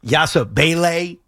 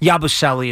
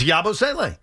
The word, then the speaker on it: Yabusele: That's what I said. It's Yabusele. I'm telling you. It's Yabusele.